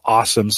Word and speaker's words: Alxum: The company called Awesome, spelled A-L-X-U-M. They pronounce it just Awesome.